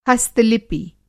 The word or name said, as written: हस्त-लिपि